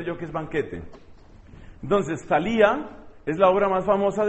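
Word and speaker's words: Thalia: Se escribe con TH, Talía. Talía.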